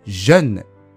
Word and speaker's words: jeune: jeune という発音が少し難しいですが、口をがっつり縦に開いて、おとえの中間のような、えという音を出します。